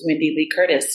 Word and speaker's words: Wendee Lee Curtis: So the easiest way to do it is all of my Broadway friends call me Wendee double E, because my name is spelled W-E-N-D-E-E. So the double E replaces the Y. My name is full of E's. Lee is spelled L-E-E. And then Curtis is C-U-R-T-I-S.